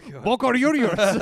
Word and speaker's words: Boca Juniors: Boca Juniors.